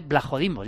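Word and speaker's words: Vlachodimos: Se escribe Blachodimos, pero dice Blajodimos. Ya sé que suena Blajodimos, un poco raro, pero Blajodimos con V.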